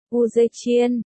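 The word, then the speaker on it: U Dê Chiên (武则天): Võ Tắc Thiên, Giản Thể, U Dơ Tiên, Phồn Thể U Dơ Tiên, Bình Âm, U Dê Chiên, phát âm tiếng Trung,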